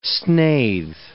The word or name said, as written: snathe